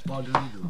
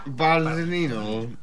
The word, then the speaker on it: Bardolino: how do you pronounce this? – Bardolino. – Bardolino.